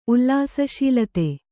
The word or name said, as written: ಉಲ್ಲಾಸಶೀಲತೆ